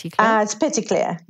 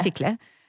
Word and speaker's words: Petitclerc: How do I pronounce her last name? – It's Petitclerc, yeah. – Petitclerc. – She's French Canadian, yes.